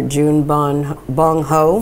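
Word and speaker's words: Bong Joon-ho: I met with, I met with uh, director June Bong Ho.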